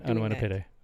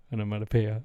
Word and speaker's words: onomatopoeia: You also said that wrong. You said it wrong. – Oh, yeah? – Yeah. We say onomatopoeia.